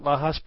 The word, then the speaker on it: Lajas: I believe the town is Lajas, or I guess would you pronounce that Lajas? L-A-J-A-S. Is that Lajas? – Yes, Lajas.